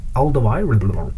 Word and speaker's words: Alderweireld: Toby Alderweireld. – Yep, that's how you pronounce it.